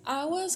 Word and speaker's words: águas: Águas, que como vimos no episódio anterior, tem essa letra G pronunciada de uma maneira bem suave, águas. Não se fala tão marcado como no português, né?